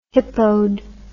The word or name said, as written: hippoed